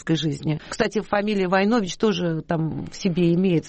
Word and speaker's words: Войнович: Есть какие-то семейные предания, и есть у меня книга, между прочим, из Сербии, наш род Войнович, правильное ударение Войнович.